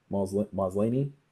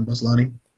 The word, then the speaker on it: Maslany: So Kevin Feige also gave some casting updates on the already announced Moon Knight, She-Hulk, and Miss Marvel, notably Tatiana Maslany. – Tatiana Maslany. – Maslany? I knew I said it wrong.